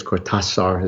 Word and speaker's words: Cortázar: There are a lot of stories by Julio Cortázar, or I guess Cortázar is how he would pronounce it since he's Latin American, that do exactly that.